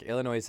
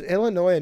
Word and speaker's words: Illinois: Back on topic, Illinois has two. Well, fossils. – Did you just pronounce the S on the end of Illinois? What are you, stupid? You know it's silent, right?